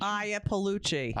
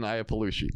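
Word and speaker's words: Iapalucci: I'm going to mispronounce her name. Adrian Iannucci. – Iapolucci. – Iapolucci. Thank you so much. – Very funny. – Adrian Iapolucci.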